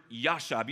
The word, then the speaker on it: yashab: Abide, in the original Hebrew, is this word yashab. You see how it's pronounced there? Yashab. Everybody say yashab.